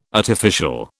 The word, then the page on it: artificial: British English